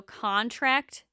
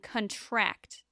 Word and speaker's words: contract: Stress on the first syllable is a noun, and stress on the last syllable is a verb. So contract and contract.